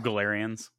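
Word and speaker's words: Galerians: And then you brought up, how is that pronounced? Glarians? – Oh, Galarians.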